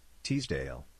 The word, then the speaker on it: Teasdale: Teasdale.